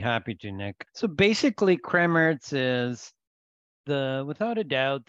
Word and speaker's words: Kremmerz: And I know I'm probably slaughtering the name. Uh, and you even told me how to pronounce it Kremmerz.